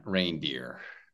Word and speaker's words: reindeer: And reindeer. So this is pronounced the same as rain. Rain. Yeah, it's a reindeer.